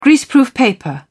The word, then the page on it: greaseproof paper: British English